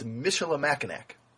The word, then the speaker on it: Michilimackinac: And when you go up north, there's a place called Michilimackinac, which is especially confusing because it's got Mish with a C that's soft, but it's followed by a bunch of C's that are hard in Mackinac. It's Michilimackinac.